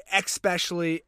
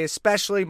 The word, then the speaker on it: especially: They say especially, especially. They throw in it like a K between the E. And so it's E-K and then specially, even though it's especially.